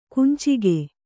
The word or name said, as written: ಕುಂಚಿಗೆ